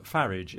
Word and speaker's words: Farage: Talking of leading intellectuals, another imminent visitor to Lesser Britain looks like being top-to-bottom Brexiter Nigel Farage, pronounced Farage if you're British, rather than the French way in which he says it.